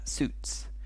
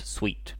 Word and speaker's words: suit: And on the other hand, all of the men usually wear suits. So they wear a suit. Pronunciation, remember, suit, suit, suit, suit, not sweet, no, suit.